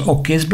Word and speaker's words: Hoquets: Die plaat is ondertussen tien jaar oud en ze is gemaakt door de ja, het wordt geschreven als H-O-Q-U-E-T-S, want daar begint hem op al. De, je moet het uitspreken als okes blijkbaar, de okes.